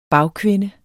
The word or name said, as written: bagkvinde